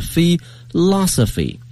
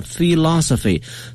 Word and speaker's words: philosophy: 再说一遍 ,philosophy, 它的发音大家注意了是 philosophy, 重音在第二音节上 philosophy。